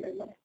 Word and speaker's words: Lima: That be in am I saying it right, Lima? – Yeah, it'll be in Lima.